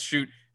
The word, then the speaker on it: Schutt: I'm here to update that we have learned since Thursday that his name is pronounced Schutt.